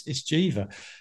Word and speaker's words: Geva: And they said, it's Jeeva. And that's the truth. I didn't know. And I've told Jeeva this story myself. I mean, I didn't know that was how you pronounce the name.